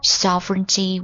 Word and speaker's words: sovereignty: Hong Kong was a British colony from 1841 until sovereignty was returned to China in 1997.来看一下 colony, C-O-L-O-N-Y, colony, 殖民地的意思 sovereignty, sovereignty, S-O-V-E, sovereignty, R-E-I-G-N-T-Y, G 是一个不发音的字母 sovereignty, sovereignty, sovereignty, 主权的意思Until sovereignty was returned to China in 1997.